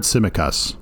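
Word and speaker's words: Tsimikas: You know, they started with Samaikas, Kostas Samaikas. How do you say that? I think. – I've heard Simikas.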